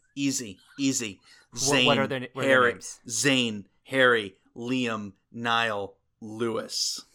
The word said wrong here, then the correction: Louis: Oh, no. It's Louis. It's pronounced Louis. – Oh, who gives a shit? – It's really not Lewis, because people will come for you.